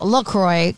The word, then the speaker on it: LaCroix: Officially, it's pronounced LaCroix because it rhymes with enjoy.